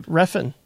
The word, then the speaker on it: Refn: I feel that Nicholas Winding Refn, how do you pronounce his last name? – Refn, I think. – Okay, so it's just like it spelled.